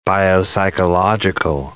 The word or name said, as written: biopsychological